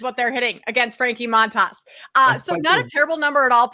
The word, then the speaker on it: Montas: And so obviously facing them again today, going up against Frankie Montas, who's pitching for Milwaukee against Montas. Yeah, I'm saying that right. Against Montas, the Mets are hitting as a team 275.